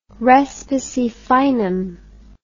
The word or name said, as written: respice finem